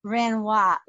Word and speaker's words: Renoir: But anyways, Lynn Renoir. Lynn, am I pronouncing your name right? – That's right, yes. – I love it. Renoir.